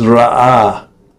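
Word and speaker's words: ra'ah (רעה): The Hebrew word for the week is ra'ah. Again, ra'ah. You've got to kind of pronounce that second ah from the back of your throat. That's the ayin. Ra'ah.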